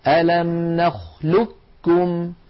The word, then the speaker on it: نخلقكم: إذن أعود فأذكركم بنطق هذا الحرف هكذا ألم نخلقكم ألم نخلقكم كاف مشددة.